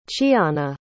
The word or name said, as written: chiana